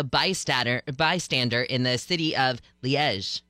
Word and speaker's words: Liège: It is Liège, apparently. Okay. And I love my pronouncer is specifically Liège, and you say the J sound at the end like Zha Zha.